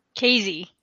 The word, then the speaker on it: Kayze: Kayze.